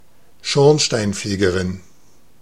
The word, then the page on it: Schornsteinfegerin: /ˈʃɔʁnʃtaɪnˌfeːɡɐʁɪn/